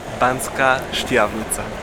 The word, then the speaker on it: Banská Štiavnica: Banská Štiavnica.